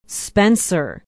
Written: spencer

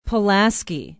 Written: Pulaski

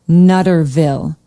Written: nutterville

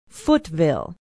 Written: footville

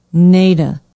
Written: Neda

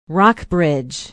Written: rockbridge

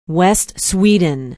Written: west sweden

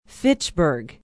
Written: fitchburg

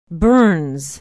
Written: burns

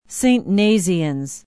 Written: Saint Nazianz

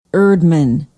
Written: erdman

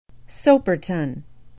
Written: soperton

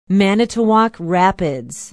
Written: manitowoc rapids

